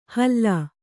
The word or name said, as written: ಹಲ್ಲಾ